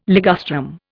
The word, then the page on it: ligustrum: Indian